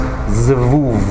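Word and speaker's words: Zevuv (זבוב): And the Zebub part, in Hebrew it's pronounced Zevuv.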